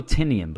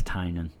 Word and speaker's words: Tynion: Well, we're going to continue the Batman trolley with Batman Eternal number 51 next, written by James Tynion IV, or Tynan, I've heard it pronounced. We always go Tynion, but I think it's Tynan.